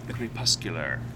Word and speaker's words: crepuscular: Crepuscular. – Thank you. – Which is not the same as corpuscular.